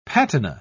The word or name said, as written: patina